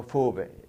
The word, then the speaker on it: Vowe: Theo Fowe. V-O-W-E. – Mm-hmm. – You know, in German, the V is pronounced like an F, and the W like a V.